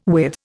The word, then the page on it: with: Indian